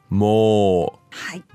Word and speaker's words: もう: So, as we saw time and time again, the word already is もう。はい。 Not to be confused with も。 – No. – This is a long O, も。